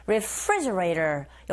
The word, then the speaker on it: refrigerator: Re가 아니고 re 강세는 refri e 이 부분에 있으니까 refrigerator 그리고 T 사운드 있죠 맨 뒤에. 강모음과 양모음 사이에 자리 잡고 있습니다. 이런 경우에는 트가 아니라 르에 가깝게 들려서 우리 귀에 들리는 소리가 즉 원어민의 입에서 나오는 소리가 refrigerator, refrigerator 이렇게 발음되니까 그대로 흉내내시면 되겠어요.